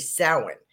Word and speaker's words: Samhain: S-A-M-H-A-I-N, we would say Samhain, is actually Samhain. It's pronounced Samhain, S-O-W-I-N. So that's how it's pronounced in the Celtic world.